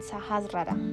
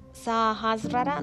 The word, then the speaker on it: Sahasrara: Sajas rara, lo pronuncié mal, Sajas rara, rara,